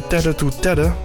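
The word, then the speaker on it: Tete2Tete: En ook op 19 september Plot Twist bij Tedde to Tedde. Zo schrijf je dat niet, maar zo spreek je dat misschien wel uit. Het is T-E-T-E en dan het cijfer 2 en dan T-E-T-E.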